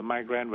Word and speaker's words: migraine: Thì cái từ migraine viết là M-I-G-R-A-I-N-E, thì tiếng Mỹ đọc là migraine và tiếng Pháp đọc là migraine.